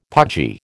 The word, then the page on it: pudgy: American English